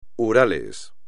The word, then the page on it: Urales: espagnol